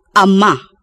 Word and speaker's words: ama: The four types of stresses are A. End stress, where the last syllable of the word receives a stronger emphasis than the others. – Anak. A-nak. Ama. A-ma.